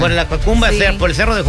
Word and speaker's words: Jacumba: Yocumba. – Jacuna. – Jacumba. – Sí, Jacumba Matata.